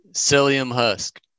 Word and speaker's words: Psyllium: How do you say it? Slimian? – Psyllium husk. – Psyllium husk.